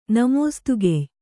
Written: ನಮೋಸ್ತುಗೆಯ್

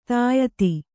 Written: ಧೀಯುತ

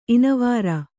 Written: ಇನಿವರ್

